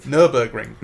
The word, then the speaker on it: Nürburgring: One day you'll pronounce it correctly. I know you will. Oh, what is it? – Nürburgring. – Nürburgring. – Nürburgring. – Excuse-moi. – It's German. Yeah, no, we didn't do that for 24 hours, but it was cracking fun.